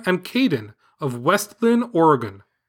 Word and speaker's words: Oregon: Today, in your Bigfoot podcast, you, like most non-Oregonians, said Oregon. And when we hear that, we cringe. Smiley face emoji. The correct pronunciation is Oregon, with the emphasis on the first syllable.